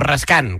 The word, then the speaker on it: Raskin: es Raskin, o Raskan, que es como se pronuncia,